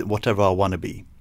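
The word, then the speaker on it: Watevra Wa'Nabi: now, this is going to be difficult to pronounce, but it's Queen Whatever Wanabe. I think it's kind of... I've not pronounced that right. It's meant to be pronounced Whatever I Wanna Be. So it's Queen Whatever I Wanna Be.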